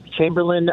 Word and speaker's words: Chamberlain: Chamberlain appeasing Hitler. Am I saying that right? Yes.